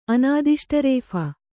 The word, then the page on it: ಅನಾದಿಷ್ಟರೇಫ: Kannada